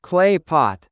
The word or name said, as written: clay pot